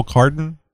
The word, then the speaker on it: cardan: And you'll need either CV or, and am I saying this right? Because I always get the sweater mixed up with it. The double cardan? Is that, you got it right.